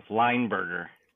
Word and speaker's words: Leinberger: Leinberger.